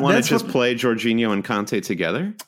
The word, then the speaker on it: Jorginho: There's also talk that uh, Jorginho, who's a defense, I don't know if I'm pronouncing that correctly. – I think uh, it's Jorginho. – Jorginho, thank you.